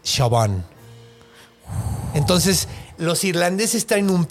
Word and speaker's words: Siobhan: Siobhan se escribe, se dice Siobhan.